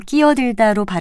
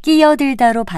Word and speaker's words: 끼어들다: 그런데 이 표현은 글자 그대로 끼어들다로 발음해도 되고요. 끼어들다로 발음해도 됩니다.